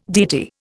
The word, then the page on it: deity: Indian